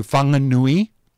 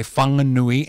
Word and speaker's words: Whanganui: So now when you enter into Whanganui, your Google Maps will say Whanganui instead of Whanganui.